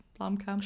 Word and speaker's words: Blomkamp: Is that, did I say his name right? Blomkamp? – Sure. – Yeah, sure. Yeah, we said it.